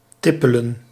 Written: walk

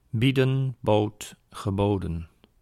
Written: bieden

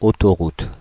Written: highway

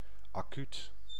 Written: immediately

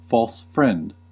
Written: false friend

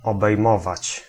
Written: comprendre

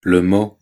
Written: mot

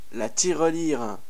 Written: tirelire

